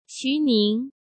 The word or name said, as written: Xu Ning